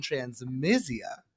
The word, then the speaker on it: transmisia: For those who don't know, there's a difference between transphobia and transmisia. Did I say that right? Is it transmisia? Transmisia, yes.